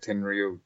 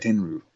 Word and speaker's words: Tenryu: Tenryu gets uh, and I keep pronouncing it wrong. It's Tenyu, no? Tenryu. Ten, yeah. Tenryu. There you go.